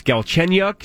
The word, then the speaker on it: Galchenyuk: He gets Alex Galchenyuk.